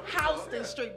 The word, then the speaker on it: Houston: Because I've been to New York, and so they call it street. Houston Street, but it's spelled Houston.